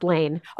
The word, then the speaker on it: Lahn: You mentioned that the guy that Zach Lon, is it Lon? Is that how it? – It's well, it looks like lawn, it's pronounced lane.